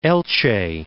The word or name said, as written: Elche